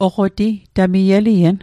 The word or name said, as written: oqoti